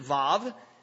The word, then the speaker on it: Vav: I know it looks like Vav here in your Bible, perhaps printed that way, but she pronounced it Vav. And it's the Hebrew letter Vav, and it's the V sound, the V as in victory type sound in a word.